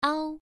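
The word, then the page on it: ao: tone 1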